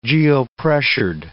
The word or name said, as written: geopressured